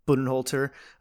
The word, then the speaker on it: Budenholzer: Budenholzer.